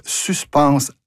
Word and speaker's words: suspense: Il existe un mot identique, mais de genre féminin, qui se prononce suspense à la française.